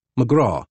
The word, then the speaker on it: McGrath: The surname of Andrew Gordon McGrath is pronounced in the Scottish style, McGraw.